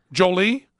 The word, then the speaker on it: Jolie: Angelina Jolie, you know, is good. She's very attractive. But am I saying it wrong? Jolie? It's Jolie.